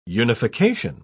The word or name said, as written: unification